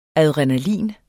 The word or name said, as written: ADRENALINET